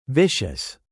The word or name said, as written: vicious